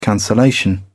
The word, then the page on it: cancellation: British English